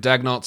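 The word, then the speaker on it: Daigneault: Mark Dagnott, I wish Dagnott knew how to pronounce his own name, but he's going with Dagnott, so I guess prayers to him.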